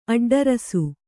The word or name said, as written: ಅಡ್ಡರಸು